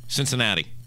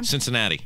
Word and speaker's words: Cincinnati: Cincinnati. – One more time? – Cincinnati.